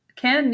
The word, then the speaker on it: Cannes: Nice. Cannes, Nice. Those are the pronunciation.